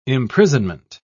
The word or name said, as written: imprisonment